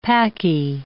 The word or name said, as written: Paki